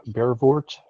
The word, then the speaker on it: Brevoort: Brevoort.